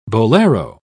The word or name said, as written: bolero